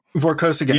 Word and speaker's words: Vorkosigan: The Miles, it may be the Vorksigan Saga. I'm not sure. How's it pronounced? Is it Vorkosigan? – Vorkosigan. – Yeah, that's correct.